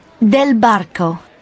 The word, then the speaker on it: Del Barco: Del Barco.